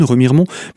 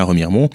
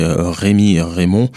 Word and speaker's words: Remiremont: Remiremont et qui ne savaient pas prononcer Remiremont, qui disaient Rémi Raymond.